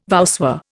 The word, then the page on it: voussoir: Indian